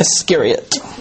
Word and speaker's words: Iscariot: Iscariot.